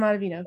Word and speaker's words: Ottavino: And then the Mets got Adam Octavian. I always get his last name wrong. With 1 year, $4 million. – Oh, Adam Ottavino.